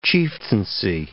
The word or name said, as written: chieftaincy